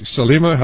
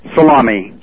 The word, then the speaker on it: Salameh: Is Salima, how do you pronounce it? – Salami. – Salami. – Yes.